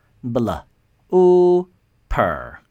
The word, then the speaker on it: blooper: 那么在今天的这个词洋相花絮这个词当中这个 double O 它要发长音 U 我们来拼读一下 Bl Ooper 重音在第一个音节 Blooper Blooper 但